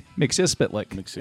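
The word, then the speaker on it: Mxyzptlk: It's Mix Yez Spitlick. Mix Yez Spitlick is how it's pronounced. Spitlick. Yeah, and he actually pantomimed it all out. Like, he spit, he licked.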